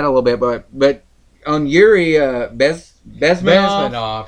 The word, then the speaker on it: Bezmenov: Yuri Bezmenov.